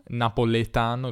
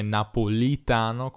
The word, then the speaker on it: Napolitano: Ecco, lui, eh, Giorgio Napoletano, come suggerisce il cognome, era di Napoli, eh, non na- napoletano, il cognome napolitano con la I, ehm, ed è ancora senatore oggigiorno.